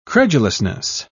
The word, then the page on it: credulousness: British English